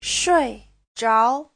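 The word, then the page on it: shuizhao: shui4zhao2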